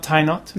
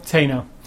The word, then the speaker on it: Tainot: Not Tainot. – No. Tano.